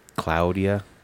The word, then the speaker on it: Claudia: Is it Claudia or Claudia? – Claudia. – Claudia. That's how you say it in Europe.